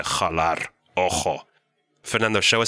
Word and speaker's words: jalar: La jota makes the jota sound, like in jalar.